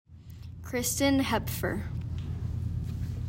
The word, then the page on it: Kristen Hepfer: CHRIS tin HEP fur